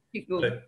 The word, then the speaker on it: Qigong: I mean, because you mentioned doing, was it Qigong? I'm not sure how to pronounce it. – Qigong.